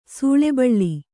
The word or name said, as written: ಸೂಳೆಬಳ್ಳಿ